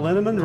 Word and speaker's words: Linneman: The equally mysterious Tracy Z, Aviva Lael, Alicia Lemus, Rebecca Hughes, Val Linneman, Rhymes with Cinnamon,